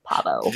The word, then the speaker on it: Pahvo: Pavo. It sounded like Parvo in the show, but it's spelled P-A-H-V-O, Pavo.